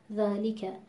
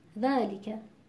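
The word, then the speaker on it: ذٰلِكَ: مثلا در کلمه ذالیکه، الان این رو با رعایت حق و مستحق حرف ما درست تلفظ کردیم، ذالیکه، یعنی علاوه بر مخرج حرف ذا درست تلفظ کردن استفال و بعد حرف لام و حرف کاف و در این حال وزن کلمه. خب ممکنه که بر اساس عادت تلاوت‌های قبلیمون بخونیم ذالیکه. خب در اینجا دیده میشه که در تلفظ حرف لام و کسره‌ای که این حرف داره اختلاس دیده میشه،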